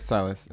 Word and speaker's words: Silas: Silas? Am I saying it right? – It's Silas.